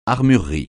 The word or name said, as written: armurerie